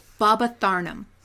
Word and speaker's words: Babatharnam: Babatharnam? How do you say that? – Babatharnam. – Oh, like that.